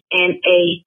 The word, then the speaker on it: Natura: I don't know how you pronounce it in English, but it's Natura.